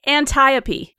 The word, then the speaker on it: Antiope: Was it Antiope? Is that how you say it? – Yeah. It's Antiope, I believe, is the Antiope. Pronunciation that they use. – Yeah. – It rhymes with Calliope. Antiope. That's the way I remembered it.